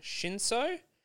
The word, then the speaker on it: Shinso: Uh, we also see um, Shiso. I think I'm pronouncing his name wrong. Shinso, the gentleman, the young boy Shinso, who can.